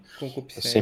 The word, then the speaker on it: concupiscência: Nós somos seres caídos, nós temos né, eu sempre pronuncio essa palavra, conscupiscência, sei lá, conscupiscência, é isso mesmo? – Concupiscência.